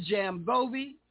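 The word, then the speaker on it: Zamajobe: So it's featuring Zam Jovi, Z-A-M, she don't pronounce the A, J-O-B-E, Zam Jovi, with Lee Ritner.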